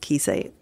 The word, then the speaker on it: Kiese: I think his first name is pronounced Kiese, K-I-E-S-E.